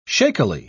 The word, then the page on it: shakily: British English